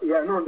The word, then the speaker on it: Yaanuwn: It's pronounced Yaanuwn, dependent on the dialect of what galaxy you're in, the pronunciation changes.